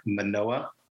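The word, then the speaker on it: Mānoa: Manoa.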